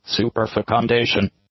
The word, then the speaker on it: superfecundation: Superfecundation. Superfecundation. – Yeah, so we're not crazy. Who would put F-U-C in the middle of that word? – No. – Okay, here's what it means. Superfecundation